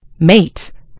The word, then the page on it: mate: American English